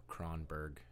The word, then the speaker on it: Kronberg: I accept multiple pronunciations, but Kronberg is the general standard. – Oh, that's good. – Kronberg is Kronberg. – Also okay. I don't have like a no, it's Kronberg.